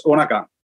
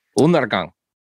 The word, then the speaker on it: Undergang: It's Undergang. Is that right? – More or less, yeah. It's not really that easy, I guess, for people not speaking the Danish thing. We just pronounce it like it's two N's. So it's Undergang. – Undergang.